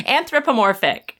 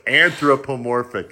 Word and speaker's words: anthropomorphic: So it was pro-native, but still, like, you know, anamorphic. Wait. – Anamorphic. – Wait. – Why do I feel like there's another syllable? – I feel like we didn't say it. Anthropomorphic. – That's it. – There we go. – Anthropomorphic. You know, furries.